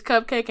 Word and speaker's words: Cupcake: My name is Cupcake, and that's spelled C-U-P-C-A-K-E.